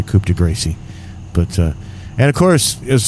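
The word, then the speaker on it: coup de grâce: If anyone would like to give him the old coup de grace right now, they can do it. But that is the proper French pronunciation. – Coup de grace. – Yes. – Yes, your French is very good.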